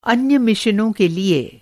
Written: मिशन

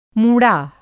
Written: मूँड़ा

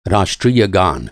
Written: गान

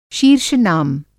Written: शीर्ष-नाम

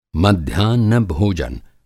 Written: मध्याह्न भोजन

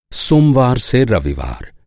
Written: रविवार

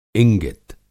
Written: इंगित

